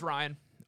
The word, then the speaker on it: Ryan: My name's Ryan, R-Y-A-N, for those of you who are curious on how to spell it.